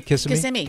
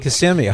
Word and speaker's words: Kissimmee: And Geraldine in Kissimmee, am I saying it right? Kissimmee, does that sound right? Kissimmee? – Kissimmee. Mm-hmm. – Kissimmee, okay.